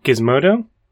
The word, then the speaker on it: Gizmodo: This is a Gizmondo article. – Gizmodo. – Huh. – Do you pronounce it Gizmodo? Is that what you said? – No, Mondo. I thought there was an N there. – There's no N.